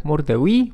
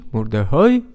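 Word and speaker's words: Mur de Huy: Walońska strzała, e, wyścig jednego podjazdu e, Murdewi, Murdehoi, zależy e, jak tam kto wymawia, czy po walońsku, czy francusku, czy po flamandzku.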